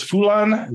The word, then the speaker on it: Foulon: was written by Chris Foulon. Is that am I pronouncing that right? – Yes.